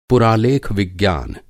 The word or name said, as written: पुरालेख-विज्ञान